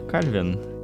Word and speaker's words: Кальвин: Почему Кальвин? Потому что ударение такое вроде как. А, ладно, окей. – А, потому что он... Нет, нет, нет, понятно, он, фран... он из Франции. Ударение на последний слог.